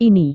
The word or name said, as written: ini